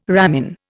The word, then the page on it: ramin: Indian